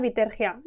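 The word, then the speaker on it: Bitergia: Bueno, para aquí, para España, Vitergia, está bien. – ¡Vitergia! Y no sé, ¿nos podrías contar un poquito lo que haces? – Bueno, pues bueno, yo en Vitergia llevo sobre todo la parte de estrategia de marketing.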